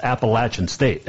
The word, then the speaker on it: Appalachian State: was there, and their SID at the time came over and made sure I knew how to pronounce Appalachian State, because it's the Appalachian Mountains, but it's Appalachian State. That's what he told me.